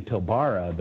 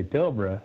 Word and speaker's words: Pilbara: I keep saying Pilbara, but it, you know, instead of Pilbara, you got to say Pilbara.